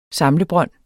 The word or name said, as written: samlebrønd